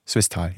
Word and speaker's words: Swisttal: Herr Rentsch aus Swisttal. Habe ich das richtig gesagt? Swisttal, ja, ne? Herr Rentsch, hallo, hören Sie mich? – Das stimmt, ja.